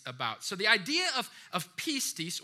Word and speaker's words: pistis: and that is pistis. All right, we'll put it on the screen so you can see what that looks like. Maybe take note of it. Pistis would be how it is pronounced.